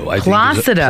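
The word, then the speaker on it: Plácido: Well, Placido Domingo is leaving the New York Metropolitan Opera. Placido. – Placido. – Placido is how they pronounce it.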